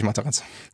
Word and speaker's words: Matarazzo: Ähm, das liegt dann an, an Matarazzo. Wo ich gelernt habe, den spricht man Italienisch aus, obwohl er aus New Jersey kommt. Ich glaube, er heißt wirklich Matarazzo.